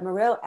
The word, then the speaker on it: Morrill: And one of those, one of the policies or the pieces of legislation that was so formative was the Morrill Act. I'm pronouncing that right, right? – Morrill Act. Yeah, the Morrill Act.